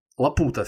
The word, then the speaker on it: Лапута: А, летающий остров, вот боюсь ошибиться с ударением, Лапута или Лапута – тоже остров. Главное тут испанский язык, конечно, не использовать. – О боже ты мой. Да, действительно. Лапута все-таки.